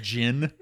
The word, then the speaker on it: djinn: a djinn. – Yeah. – The D is silent.